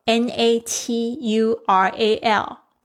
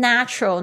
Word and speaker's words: natural: Number eight, natural, natural, N A T U R A L，自然的。那我这边想要提点一下，它的名词是 nature。 nature 它的发音呢就跟它有点不太一样。前面 natural 你会发现那个 a 变成蝴蝶音，你的舌头要向下压 natural。